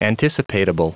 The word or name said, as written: anticipatable